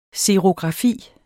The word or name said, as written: xerografi